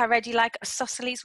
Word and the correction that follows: Isosceles: wine. Is that how you say it? – I absolutely love isosceles wine.